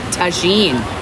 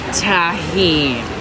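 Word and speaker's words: Tajín: And our featured menu item here is the pineapple skewer with tahine seasoning that I always want to call tahine. – Spelled tahine. – Pronounced tahine.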